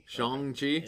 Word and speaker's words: Shang-Chi: How are they pronouncing it? – Shang-Chi. – Shang-Chi. – So you've been pronouncing it's supposed to sound like Sean almost. – Oh, okay. – So you've been pronouncing it wrong for the last 50 years or so.